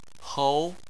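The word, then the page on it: hou: tone 2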